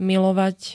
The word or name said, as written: milovať